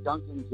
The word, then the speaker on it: Duncan: that's funny because my name is, like, it's spelled D-U-N-C-A-N, you know? And mm-hmm. People are always like, I say it to them. I was like, what's your name? I'm like, Ducan Jay, and they're like, Ducan, like the donut?